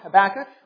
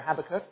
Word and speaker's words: Habakkuk: Well, congregation, I invite you to turn with me in God's Word to the prophet Habakkuk, or Habakkuk, depending on how you pronounce his name.